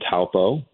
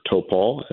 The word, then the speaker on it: Taupo: So before we got to Wellington for the second group game against the Netherlands, we stopped in Taupo, or Topol, as the locals properly pronounce it.